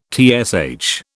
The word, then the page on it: TSH: British English